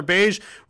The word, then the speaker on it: Beige: Heather Beige rhymes with rage.